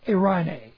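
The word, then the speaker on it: Eirne (εἰρήνη): Eirne, E-I-R-N, long E, means peace. Harmony. It's where we get the girl's name, Irene.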